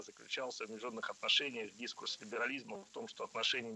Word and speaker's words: дискурс: А запомнить ударение в этом слове легко с помощью другого простого слова «курс». Курс – дискурс.